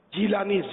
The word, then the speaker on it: جیلانی: بعض لوگ جیلانی بھی بولتے ہیں، لیکن معلومات کی لغت کے ذریعے معلومات کروائی تو پتا چلا کہ وہ درست تلفظ جیلانی زیر کے ساتھ ہے، جیلانی نہیں ہے.